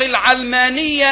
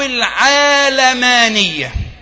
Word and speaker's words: العلمانية: العلمانية بفتح العين نسبة إلى علم، والعلم بمعنى العالم، فتنطق العلمانية أو العالمانية، كلاهما صحيح.